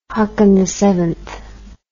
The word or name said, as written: haakonvii